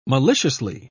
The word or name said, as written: maliciously